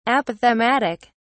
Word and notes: apophthegmatic: British English

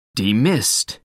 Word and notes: demist: British English

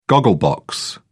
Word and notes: goggle box: British English

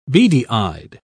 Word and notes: beady-eyed: British English

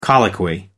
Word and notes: colloquy: American English

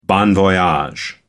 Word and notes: bon voyage: American English

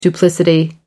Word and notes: duplicity: American English

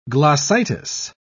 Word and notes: glossitis: British English

